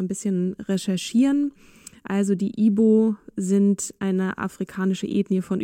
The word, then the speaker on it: Igbo: Ihre Familie gehört zur Bevölkerungsgruppe der Igbo. Es wird Igbo geschrieben, aber das G spricht man nicht mit.